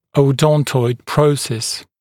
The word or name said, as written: odontoid process